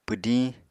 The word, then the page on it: pwdiñ: Apatani